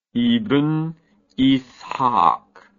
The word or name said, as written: ibn ishaq